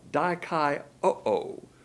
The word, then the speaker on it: dikaioo: The word justified here is dikaioo, D-I-K-A-I-O-O. In the Greek, when you have two O's on the end of the word, you pronounce it O-O. So dikaioo.